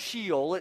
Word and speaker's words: Sheol: And he uses the word called Sheol, okay? Sheol, and it's spelled S-H-E-O-L. It's not Sheol, it's Sheol.